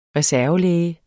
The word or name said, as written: reservelæge